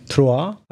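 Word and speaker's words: Troyes: Troa, hör du det? – Det är ett bra uttal.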